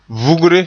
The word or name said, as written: vugre